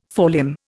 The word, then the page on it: forelimb: Indian